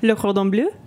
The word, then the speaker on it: Le Cordon Bleu: Le Cordon Bleu.